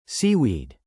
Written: seaweed

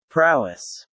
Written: prowess

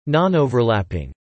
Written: nonoverlapping

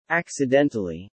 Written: accidentally

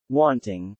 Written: wanting

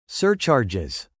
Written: surcharges

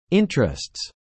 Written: interests

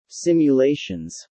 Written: simulations

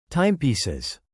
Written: timepieces

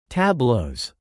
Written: tableaus

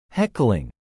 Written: heckling

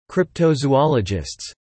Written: cryptozoologists

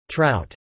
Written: trout

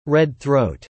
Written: redthroat

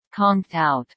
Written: conked out

